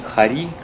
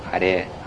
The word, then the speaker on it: Харе: Пишется не Хари Кришна, а Харе. Харе, Харе, Харе. Хари, я не знаю, кто написал, Хари — это имя Кришны, а Харе — это обращение к Шримати Радхарани.